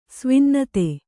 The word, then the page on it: ಸ್ವಿನ್ನತೆ: Kannada